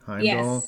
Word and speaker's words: Heimdall: Did I say that correctly? Heimdall. – Yes.